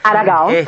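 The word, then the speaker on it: Aragão: Espero haberlo pronunciado bien, Aragao, ¿no? – Aragao. – Correcto, este músico brasileño, cantante.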